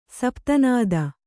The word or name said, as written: ಸಪ್ತನಾದ